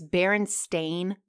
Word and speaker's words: Berenstain: She posted, it's Berenstain Bears, like the way it's spelled.